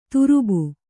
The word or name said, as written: ತರುಬು